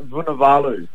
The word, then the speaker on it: Vunivalu: Solly Lassie Vanaroo. – Solly Lassie Vunivalu. – Smithy. How do you say it, Smithy? – Solly Lassie Vunivalu.